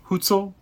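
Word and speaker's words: Hutsul: Like, the, and how do you say this? These people again, Sonia? The Hutzul? Hutzul? Okay. – Yeah.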